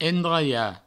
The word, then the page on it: endraiar: endraia